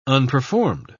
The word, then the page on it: unperformed: British English